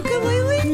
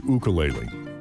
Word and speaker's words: ukulele: No, it's pronounced, it's actually pronounced, that's the correct pronunciation, is ukulele. – Yeah. – If you pronounce it ukulele, you ain't local, brudda.